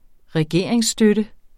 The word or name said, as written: regeringsstøtte